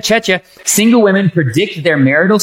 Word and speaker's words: Czechia: In Chechia. – Chechia. – C-Z-E-C-H-I-A, which Manny apparently knows how to spell that.